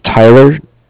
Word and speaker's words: Tyler: My name is Tyler, T-Y-L-E-R, and I'm calling with Cardiff. It's spelled C-A-R.